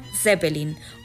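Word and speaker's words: zépelin: Además, esta palabra se recoge con dos acentuaciones válidas, tanto Zeppelin con tilde en la primera E como Zeppelin con tilde en la I.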